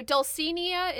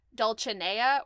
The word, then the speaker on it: Dulcinea: Dulcinea, or rather, okay, so Dulcinea is the way that Bismarck says it. It should actually be pronounced Dulcinea or Dulcinea, because it's a Spanish word.